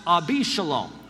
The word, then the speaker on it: Absalom: And so Ab Shalom, but it's pronounced Abishalom. Ab meaning father, Shalom, peace.